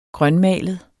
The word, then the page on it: grønmalet: [ ˈgʁɶnˌmæˀləð ]; [ -ˌmæːləð ]